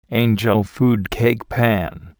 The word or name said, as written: angel food cake pan